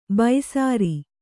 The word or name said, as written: ಬೈಸಾರಿ